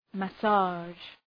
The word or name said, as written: massage